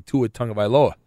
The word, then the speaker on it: Tagovailoa: So I knew right away how to say Tua Tagovailoa.